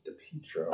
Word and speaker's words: DiPietro: His last name is pronounced DiPietro. And I know this because Dave Lane, photographer for the Morning Sentinel, uncovered one of Justin's nicknames is Peach or Peaches, as in DiPietro.